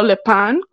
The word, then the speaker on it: Lupin: Lupin, o Le Pan, como lo quieran pronunciar. Y pues nada,